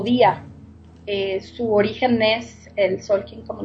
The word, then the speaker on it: Tzolkin: me encantó y bueno, además de todo, me gustaría que nos platicaras, claro, qué es el, a ver si lo estoy pronunciando bien, Solkin. – Así es, muy bien pronunciado.